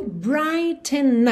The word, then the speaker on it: brighten: Então, and soon I will be there to brighten up. Atenção! Brighten se escreve B-R-I-G-H-T-E-N. Esse GH é completamente mudo, uhum.